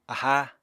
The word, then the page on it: aháa: Apatani